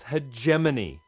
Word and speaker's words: hegemony: Hegemony? – Oh, jeez. No wonder it's frayed around the edges. Words are not meant to stand up to their secondary pronunciations. It's hegemony. Hegemony. – I never knew that.